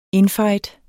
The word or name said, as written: infight